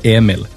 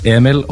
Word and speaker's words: Emil: And then you have the Emil, which you actually can pronounce it two ways in Norway, either Emil or Emil, like you guys do. So I'm fine either way.